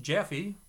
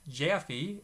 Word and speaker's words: Jaffe: Both Mark and my last name is spelled J-A-F-F-E. His name is pronounced Jaffe, whereas I pronounce my name Jaffe.